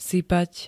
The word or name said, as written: sypať